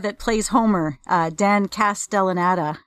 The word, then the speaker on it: Castellaneta: He's got this little purple assistant guy who's sassy but also rather smart and helpful, and he's voiced by uh, Dan Castanella.